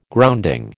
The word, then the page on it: grounding: American English